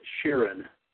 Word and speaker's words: Sheeran: Would you rather listen to Bruno Mars or Ed Sheeran? I don't know how you pronounce his name. – Yeah, you got it, Sheeran.